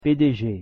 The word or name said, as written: PDG